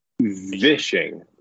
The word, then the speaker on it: vishing: We heard voicishing. How did you say that? – Vishing.